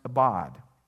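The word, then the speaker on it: abad (עבד): The Hebrew word that's translated work there is pronounced abad.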